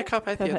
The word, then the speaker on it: Carpathia: Several of those died while they were on the Carpathia? Do I keep saying that right? – Yeah, Carpathia, that's right, yeah.